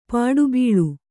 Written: ಪುಟಬಾಳೆ

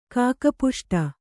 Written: ಕಾಕಪುಷ್ಟ

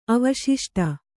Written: ಅವಶಿಷ್ಟ

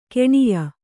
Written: ಗುಣ್ಯ

